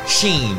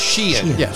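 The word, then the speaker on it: Shein: Full disclosure, I thought this business is pronounced... Sheen, but it's pronounced Sheehan.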